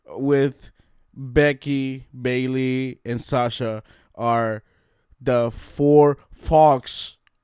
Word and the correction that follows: faux: You're also saying that wrong. It's faux. It's French.